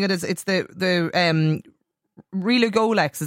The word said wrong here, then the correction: Relugolix: is that right? Is that how you... – Yeah, Relugolix.